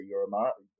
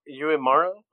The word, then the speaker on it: Uemura: Would you say Yurimara? How would you say that? – Sure. – No, I want to know how you would say it. – Well, Uemura, I think that's Uemura.